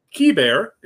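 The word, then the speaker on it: Keibert: They got Kiber, is how he has said he wants his name to be pronounced.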